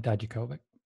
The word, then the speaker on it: Dijakovic: When Dijakovic, is that how they say his name now? – Yes, Dominik Dijakovic.